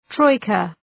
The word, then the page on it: troika: ‘trɔıkə